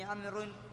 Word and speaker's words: عمرو: عليكم، هذه الواو يا إخوان، واو عمرو، هذه تكتب ولا تنطق، وهذا يجهله كثير من الناس، ولذلك يقولون عمرو، ما في شيء اسمه عمرو، ولا في أحد يسمى عمرو، عمرو ليس اسما، الاسم عمرو بس، هذه الواو زائدة تكتب ولا تلفظ.